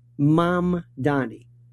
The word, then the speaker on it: Mamdani: Mamdani. First point is a very simple thing. Three syllables. Mom, like mom and dad. Donny, like Donny Osmond. Mamdani. Simple. Mamdani.